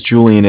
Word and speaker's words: Julian: I-F-F as in Frank. And my name is Julian. It's J-U-L-I-A-N.